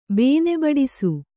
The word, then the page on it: ಬೇನೆಬಡಿಸು: Kannada